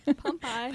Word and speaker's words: Pompeii: Pompeii. – Yeah. I always want to say Pompeii. – Pompeii.